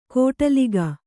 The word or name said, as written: ಕೋಟಲಿಗ